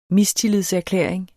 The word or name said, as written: mistillidserklæring